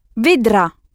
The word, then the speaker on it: vedrà: Vedra, c'est ça. Il ne faut pas oublier d'insister sur la dernière syllabe, sur le « ro » et le « ra ». Ro et ra.